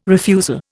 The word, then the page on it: refusal: Indian